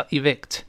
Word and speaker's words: evict: 哎，依法将房客轰出去叫 evict。注意这个发音，字母 i 发短音，e e v v evict evict 这么一个声音啊。